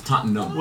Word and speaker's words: Tottenham: The H is silent. It's Tottenham.